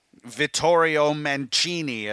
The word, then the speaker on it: Vittorio Mancini: Vittorio Mancini, as I'm told is the proper way to pronounce it. I'm probably still not putting enough Italian flair on that.